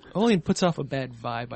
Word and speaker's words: Oliand: I'm playing Oleand. O-L-I-A-N-D. The D is silent.